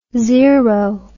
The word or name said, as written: xero